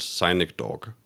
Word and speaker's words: Sonic Dog: Det, det udtales Sonic Dog. – Sonic Dog? – Ja, Sonic Dog, præcis.